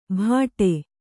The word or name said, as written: ಭಾಟೆ